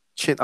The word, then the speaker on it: Qin: Chin. I think it's Qin, pronounced yeah. – Chin. – They pronounce it Chin.